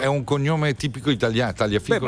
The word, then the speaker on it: Tagliafico: Sì, si pronuncia così. – Ma no, in Inghilterra. In Tagliafico. Non si è mai sentito un argentino dire GL? – Ma magari dicono Tagliafico.